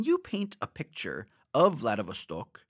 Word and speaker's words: Vladivostok: Vladivostok.